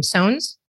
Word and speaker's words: Soans: Soans? – Yes. Yes. Justine, it rhymes. Soans. It rhymes with Jones.